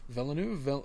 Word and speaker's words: Villeneuve: How do you pronounce it? I call it Villeneuve. – Villeneuve. – V-U-E at the end, so it's Denis, though.